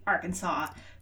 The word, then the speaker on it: Arkansas: Some of my Kansas history websites that I looked at were very specific that although it's spelled like the state Arkansas, the river is pronounced Arkansas, which hopefully they were correct.